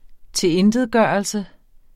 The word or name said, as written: tilintetgørelse